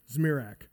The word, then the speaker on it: Zmirak: How do you say his name? John Zmirak. Zmirak. No N.